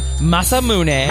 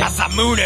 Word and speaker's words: Masamune: They are called Masamune. – Masamune. – And Muramasa. – Muramasa. – Now, for Amadeus, man. – For God's sake, man. – Those are the only accepted pronunciations.